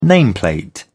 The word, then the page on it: nameplate: British English